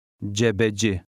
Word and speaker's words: Cebeci: Put the stress on the last syllable. – Cebeci.